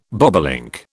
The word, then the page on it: bobolink: British English